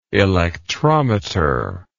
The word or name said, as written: electrometrically